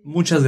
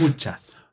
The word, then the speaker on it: muchas: Gracias, muchas gracias. – Gracias, muchos gracias. – Muchas. Muchas gracias. – Muchas gracias. – Yes, be careful there.